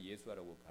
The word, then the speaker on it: 예수아: 이스라엘 백성들 가운데 메시아 그리스도를 예수아를 그 사람들 예수아라고 발음하는데 예수아를 예수를 믿는 사람들이 늘어나고 있습니다.